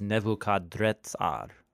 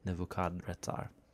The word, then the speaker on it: Nebuchadrezzar: Yeah, the Babylonian name is Nebuchadrezzar, with an R instead of an N. Not Netzar, but Retzar. Nebuchadrezzar.